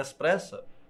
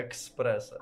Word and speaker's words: еспресо: Не еспресо, як правильно говорять, а експресо.